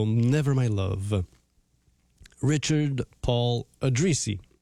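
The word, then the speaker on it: Addrisi: per parlarvi di Dick Addrizzi, o Addrizzi volendolo pronunciare in italiano,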